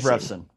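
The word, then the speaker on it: Revsine: That was amazing because I looked at, I think it was BTN's Dave Revson, if I'm pronouncing that correct. – Dave Revson. – Yeah, who tweeted that out initially.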